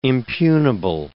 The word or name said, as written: impugnable